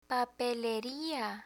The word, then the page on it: papeleria: spanish